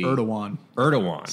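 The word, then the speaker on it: Erdoğan: Erdogan in D.C. – Erdogan. Silent G. – Okay. I'm pronouncing that incorrectly.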